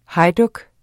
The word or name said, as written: hejduk